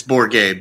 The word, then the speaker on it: Bourget: It's Bourget, but it is Bourget. Damn it. Welcome to the show, Gerald. Sorry I got your name wrong. For the second straight week in a row, I've gotten someone's last name wrong. Good start. – It's a tough one, so don't beat yourself up. – It is. I always try to go too French.